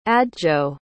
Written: adjo

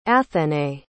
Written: athénaïs

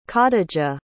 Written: khadija

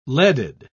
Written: leaded

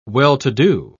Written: well-to-do

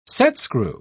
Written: setscrew

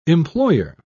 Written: employer

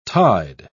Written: tide

